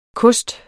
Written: KOSTENE

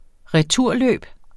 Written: returløb

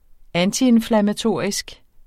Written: antiinflammatorisk

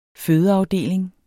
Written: fødeafdeling

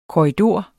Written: korridor

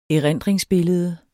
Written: erindringsbillede

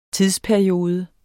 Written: Tidsperiode

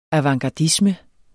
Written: avantgardisme

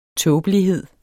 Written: tåbelighed

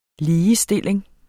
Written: ligestilling

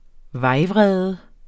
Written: vejvrede

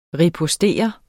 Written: ripostere

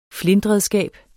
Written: flintredskab

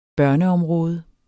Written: børneområde

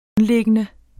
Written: grundlæggende

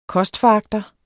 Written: kostforagter

